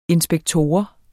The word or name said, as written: inspektor